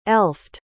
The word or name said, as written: elfed